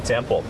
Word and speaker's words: temple: temper Welcome to the temple. – เขาบอกว่ายินดีต้อนรับเข้าสู่วัดนะครับท่านออกเสียงถูกแล้วครับ temple. ตัว L ครับ pronunciation ตัว L temple.